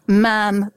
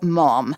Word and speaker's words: ma'am: And then very sensibly, you call her ma'am, ma'am, but not mom. – Ma'am rhymes with ham.